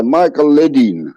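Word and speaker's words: Michael Ledeen: Ci racconti di questa vicenda di Michael Lidl affinché ne parlasse poi con Henry Kissinger, l'uomo... – Allora, ancora... Michael, sì, la pronuncia è detta Michael Ledin.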